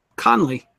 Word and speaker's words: Kahnle: is that Cannell guy. Kenil Cannell, however you pronounce his name. – I think it's Conley. – Former Yankee. Oh, Conley? Is that how you pronounce it? – Okay. K-A-H-N-L-E. Conley.